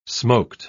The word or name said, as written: smoked